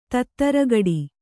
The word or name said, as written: ತತ್ತರಗುಟ್ಟು